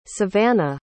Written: savanah

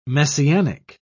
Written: messianic

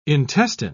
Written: intestine

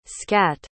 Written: scatt

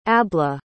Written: abla

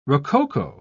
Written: rococo

